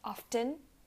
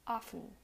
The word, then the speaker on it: often: You um, completely omit the T. You don't pronounce often, you say often.